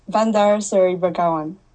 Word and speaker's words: Bandar Seri Begawan: That, I hope I probably say that so we can hear it pronounced correctly, if you don't mind. – Bandar Seri Begawan. – Oh, sounds so much better when you say it.